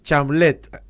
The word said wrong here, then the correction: Chalamet: algo así se pronuncia. – Pero sí, muy famoso. – Es muy famoso. Es como el actor de... – Chalamet. – Ahí está, Chalamet.